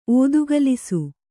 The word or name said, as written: ಓದುಗಲಿಸು